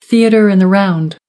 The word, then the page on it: theatre in the round: American English